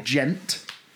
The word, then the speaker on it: djent: which is spelt D-J-E-N-T, gent, because that's the descriptor of the noise it makes on the guitar string when the pick hits the string.